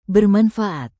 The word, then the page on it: bermanfaat: Indonesian